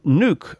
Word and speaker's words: Nuuk: And you're joining us from Nuuk, I think it's pronounced. Is that so? – Yeah, that's correct. That's the capital.